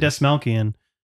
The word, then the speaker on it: Dastmalchian: Because after watching this movie, I realized that Collin Schiffli immediately followed up this movie with another collaboration with David Dastmalchian. Is that how you say his name? – Yeah, that's how I would say David Dastmalchian, who wrote this movie.